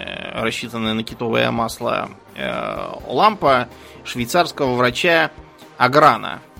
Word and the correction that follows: Арган: а, вернее, вернее, Аргана, да, Арган через А пишется.